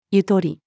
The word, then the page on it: ゆとり: female